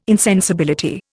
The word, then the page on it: insensibility: Indian